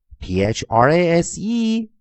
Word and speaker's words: phrase: r 加上中央元音弱元音 a shwa frap frap frap 再听一遍 phr frap frap frap 好的，在 phrase 后面的元辅一 vowel space e pronounce its name 它的 alphabet letter name 它的字母音 P H R A S E phrase phrase phrase 句子的句，再听一遍。